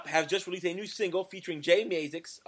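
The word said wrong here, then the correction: Mascis: Jay Maskus is how you say it, right?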